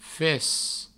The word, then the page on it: fes: Provençal